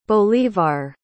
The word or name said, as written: bolívar